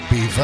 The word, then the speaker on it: Belvoir: Live from the County Asylum here in the Vale of Beaver, and it's spelt Belvoir.